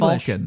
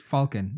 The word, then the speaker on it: Falcon: Millennium not Falcon. – English. It's English. It's Falcon, not Falcon. – Ah. – Falcon is very American.